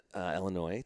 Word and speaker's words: Illinois: How do you say it? Illinois. I haven't heard, like with an E, almost like with an E at the front. – Like it's E-L-L-A-N-O-Y.